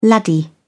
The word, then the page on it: laddie: British English